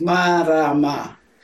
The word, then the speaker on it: marama: My name has three syllables, ma, ra, ma.